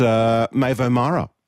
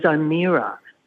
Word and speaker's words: Maeve O'Mara: You are in the true Irish way, because you'd think by looking at it was O'Meara, but that's wrong.